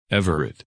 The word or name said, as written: Everett